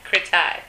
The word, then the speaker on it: Kretai: Kretai.